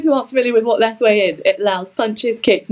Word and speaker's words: Lethwei: So with the bare knuckle fighting, Lethwei, am I saying it right? – Lethwei, that's correct.